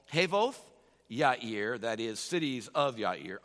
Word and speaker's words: Yair: And after him arose Jair, or you may say Yair. Probably the Hebrew pronunciation would be Yair.